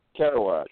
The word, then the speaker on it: Kerouac: If you like whatever his name is, how do you say that? – Kerouac.